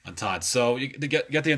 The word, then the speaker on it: entente: Okay, and then on the other side you have what's called the um, entity cordiale, which I believe I said it said it correctly. Is it entente? Entente. Yeah, I'm sorry.